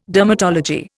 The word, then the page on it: dermatology: Indian